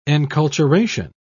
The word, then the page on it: enculturation: British English